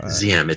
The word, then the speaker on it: Diem: Ziem. It's spelled D-I-E-M, but it's pronounced Z-I-E-M.